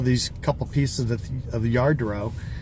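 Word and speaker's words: Lladró: One of the things was this thing called Yadro, or Yardro, and I didn't know much about it. It's spelled L-L-A-D-R-O, and it's a Spanish company made in the 50s.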